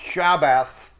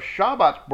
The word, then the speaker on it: Shabbat: That's spelled Shabbath. The T-H is sort of a lisping T. Shabbat. Not Shabbath. That's not how it's pronounced. It's Shabbat with a th at the end.